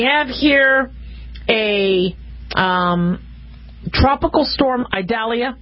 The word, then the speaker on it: Idalia: They are pronounced, oh, it's Idalia. No, Idalia.